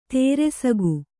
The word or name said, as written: ದರ್ಸುಕ